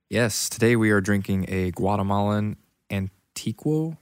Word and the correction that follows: Antigua: I cannot pronounce this. – You got it at an antique shop. – Antigua. – Oh. – Guatemala Antigua.